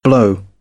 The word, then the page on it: blow: British English